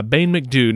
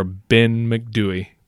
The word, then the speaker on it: Ben MacDhui: Uh, Bain McDew. Now we've we've seen it pronounced a couple of different ways. Bain McDew or Ben MacDhui. I, I think that's just like regional accent.